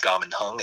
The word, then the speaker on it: Kaman Hung: My name is Gaman Hung. It's spelled K-A-M-A-N, last name Hung, H-U-N-G.